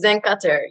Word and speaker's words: Zein Khater: Did I say it right, Zein? – Yeah, it's Zein Khater.